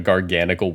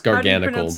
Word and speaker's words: Garganacl: It's Garganacl, because it's gargantuan plus the salt chemical formula.